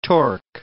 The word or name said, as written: torque